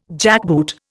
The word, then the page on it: jackboot: Indian